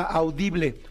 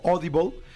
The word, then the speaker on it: Audible: Ah, hay una aplicación que se llama Audible o Audible, pero se escribe Audible y esa es la aplicación más famosa.